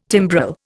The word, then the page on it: timbrel: Indian